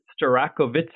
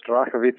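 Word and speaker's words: Starachowice: It's Strakowice. Is that correct? – Very close. Strakowice.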